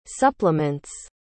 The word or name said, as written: supplements